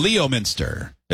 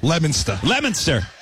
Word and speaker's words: Leominster: L-E-O, Minster. I see it. – Leominster. – Stop pronouncing the R so hard.